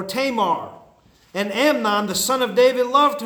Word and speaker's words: Amnon: Amon. I mean, you can say it whatever way you want, but Tamar and Amon, right? Is that how you say it? Amnon.